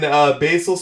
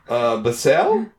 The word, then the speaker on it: Basel: So they bought I don't think you're saying that right. – Sandoz? – No, Basel. – Basel? – Say it like you're from Switzerland. – Basel. – Oh, wait, no. – Yeah. – You sound like a Viking.